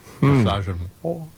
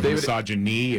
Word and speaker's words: misogynoir: Misogynoir. – Yes. – Okay, again? – Misogynoir. – Misogynoir. – Yes. – Okay. – Instead of misogyny, it's misogynoir.